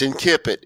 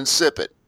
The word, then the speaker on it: Incipit: He mispronounces it. There are no soft consonants in Latin. Jeff told you right. It's in Kippit. It's not in insipid.